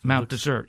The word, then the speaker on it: Mount Desert: But you say that it's, you say desert? – Mount Desert, yeah. – Even though it's spelled like desert? – That's right.